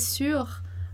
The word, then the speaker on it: sûr: Então eu vou falar Paul, tu es sûr? Marie, tu es sûr? Sûr, sûr. A pronúncia vai ser a mesma. Esse E do feminino, ele não vai aparecer na fala. Ele só vai aparecer na escrita.